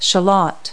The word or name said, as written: shallot